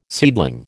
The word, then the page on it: seedling: American English